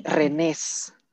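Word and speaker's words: Rennes: Aquí se aplica el ulala, señor francés, se dice Renés.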